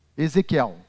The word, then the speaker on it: Ezequiel: Ezequiel.